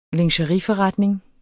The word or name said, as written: lingeriforretning